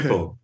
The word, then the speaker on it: Schiphol: But I've heard a pilot say, "Is it Schiphol?" – Okay. Skiffle. – Yeah. Okay. All right. So yeah, I wanted to get that right because I'm sure I've said it wrong quite a few times, you know, and embarrassed myself. So I'll keep that in mind. It doesn't look like Skiffle, but you know. – Well, it's Dutch.